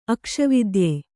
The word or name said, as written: ಅಕ್ಷವಿದ್ಯೆ